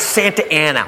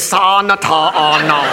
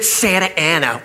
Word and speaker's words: Santa Ana: It's Santa Ana. What says Santa Ana? Look, I know I didn't finish school, but that frickin' says Sa-na-ta-na. They pronounce it Santa Ana.